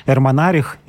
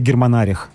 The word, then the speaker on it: Германарих: А, дело в том, что в Причерноморье находилась э, держава готов, которой руководил вождь э, Эрмонарих или германарих. Мне первый вариант больше нравится, его произношение.